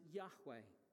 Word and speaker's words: Yahweh: You'll notice that that word Lord there is in capitals, L-O-R-D. It is a translation of the Hebrew word Y-H-W-H. We pronounce it Yahweh, but that we're a little bit unsure that that might even be the case.